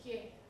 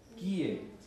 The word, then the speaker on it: kie: No se dice kie, se dice kie. ¿Por qué se dice kie? Porque ahí tenemos dos sílabas, ¿no? Dos sílabas. Hace rato que no estudio gramática, pero de español. Pero son dos sílabas, ¿no? Porque no es kie, que sería una sola sílaba, es kie. Kie significa qué cosa, Carlos?